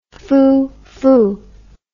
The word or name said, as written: foofoo